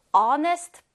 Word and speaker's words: honest: Honest boy. 이때도 H 자음으로 시작했지만 발음은 honest 모음 발음으로 선생님이 발음했죠.